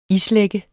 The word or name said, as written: islagte